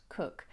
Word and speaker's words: Coke: Magna Carta owes quite a lot, I would say, to a lawyer at this time called Sir Edward Cook, spelt Coke, but I'm assured it's pronounced Cook, and for its sort of totemic status that it develops.